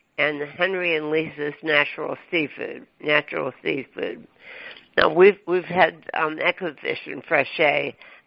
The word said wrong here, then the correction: Ecofish: So we have two different, and actually, just to start out, it's called, we pronounce it Ecofish. So Oh, okay. – as opposed to echo, but so Ecofish.